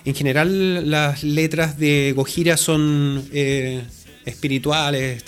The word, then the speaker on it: Gojira: Así que utilizaron, tal como se pronuncia en japonés, Gojira.